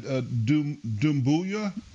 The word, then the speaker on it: Dumbuya: But last year, I was impressed with Sekou, how do you say that, Dumbuya? – Yes.